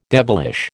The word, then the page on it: devilish: American English